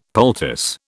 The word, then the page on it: poultice: British English